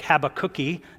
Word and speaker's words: Habakkuk: I'm going to read from the very beginning and the very end of Habakkuk, or as Alistair Begg says, you can pronounce it Habakkuk, like Habakkuki.